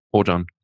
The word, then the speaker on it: Oajan: Es wird geschrieben O-A-J-A-N. – Ojan. – Hm? Ojan, ja.